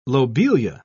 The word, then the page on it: lobelia: British English